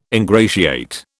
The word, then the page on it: ingratiate: British English